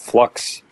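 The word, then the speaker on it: f.lux: The first one is a piece of software called Flux. I think it's pronounced that way. It's F period Lux.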